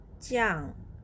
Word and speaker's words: 酱: and the word Jiang has j as a consonant pronounced as g and vowel is yang i a n g and put the fourth tone above letter a this is how you pronounce it Yang. Jiang.